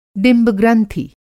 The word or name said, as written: डिंब-ग्रंथि